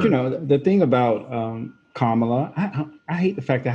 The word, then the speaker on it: Kamala: I just should just call her what I've been calling her, Kamala. That's easier. – No, I think the way you just now said it was right, Kamala.